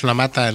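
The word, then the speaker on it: Lamata: La Mata, si lo he dicho bien, ¿no? – Sí, sí, Juan Carlos La Mata, el director de la primera adaptación de uno de los libros de los futbolísimos.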